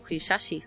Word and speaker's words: Hisaishi: Ha hecho sus bandas sonoras Joe Hisaishi. – ¿Cómo? Muy bien pronunciado, sí.